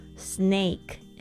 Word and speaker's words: snake: 节目主题呢是酒吧的常见零食 bar snacks，特别注意一下这个 snack 跟这个 snake 是不一样的东西哦，不要搞错了。一个是讲点心零食，另外一个是讲这个蛇 snake s n a k e。有很多同学这个发音傻傻分不清楚哦。这个 snack s n a c k，这个 a 发 a 的声音，但是呢 snake。